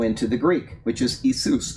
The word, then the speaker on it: Iesous: The Greeks wouldn't have said Yeshua. They would have said Iesous.